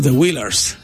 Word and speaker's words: Wailers: Entonces, bueno, estaba pronunciando mal, ¿no? The Wailers. Una disculpa.